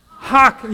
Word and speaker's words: chokmah: Can you say that with me? Chokmah. Yeah, you kind of feel like you're going to spit something, right?